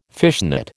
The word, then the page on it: fishnet: American English